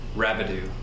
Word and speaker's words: Rabideau: Rabideau.